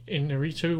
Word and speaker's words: Iñárritu: G. Iñárritu won for the second year in a row. – Rock solid pronunciation there. – Perfect. I think it was perfect.